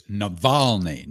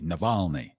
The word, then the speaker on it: Navalny: And I mispronounced his name at the beginning. I suddenly had a case of dyslexia. It's Navalny. – Navalny.